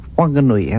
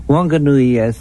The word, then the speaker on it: Whanganui: Whanganui, as pronounced, hasn't got the H in it. But the Whanganui River Trust Board says the H is there. It is their dialect to drop the H. Everybody else pronounces it, but they drop it.